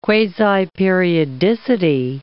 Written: quasiperiodicity

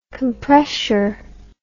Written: compressure

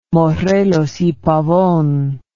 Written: morelos y pavon

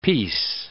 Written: peace

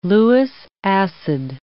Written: Lewis acid

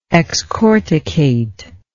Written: excorticate

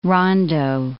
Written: rondo

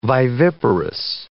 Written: viviparous

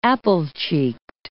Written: apple-cheeked

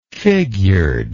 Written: figured

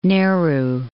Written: Nehru

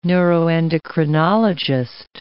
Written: neuroendocrinologist

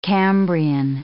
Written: Cambrian

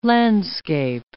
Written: landscape

